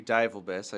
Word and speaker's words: Divelbiss: Yeah, Zachary Divelbiss.